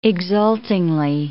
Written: exultingly

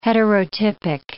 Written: heterotypic

